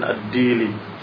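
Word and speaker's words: Ad-Dili (الدؤلي): Hadis ini diriwayatkan oleh Muslim dalam sahihnya dari riwayat Yahya bin Ya'mur dari Abil Aswad Ad-Dili. Demikian disebutkan harakatnya. Kadang ada yang membacanya Ad-Duali. Kadang ada beberapa bacaan lagi, tetapi di sini disebutkan Ad-Dili.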